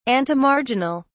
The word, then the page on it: antemarginal: British English